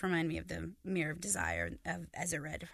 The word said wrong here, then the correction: Erised: however you pronounce it. – Erised, thank you.